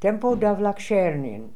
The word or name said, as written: tempo Davlakshernian